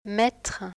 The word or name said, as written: mettre